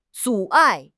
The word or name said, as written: 阻碍